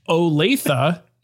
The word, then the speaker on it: Olathe: Olathe, Kansas.